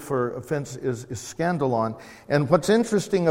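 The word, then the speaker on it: skandalon: scandalon.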